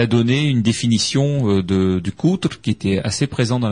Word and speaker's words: kouter: Donc c'était euh, ce, ce coutre, donc euh, qu'on décrit euh, K-O-U-T-E-R, coutre, qui peut être prononcé coutre, enfin un coutre un peu plus ouvert, qui a peut-être donné quatre, hein, voilà.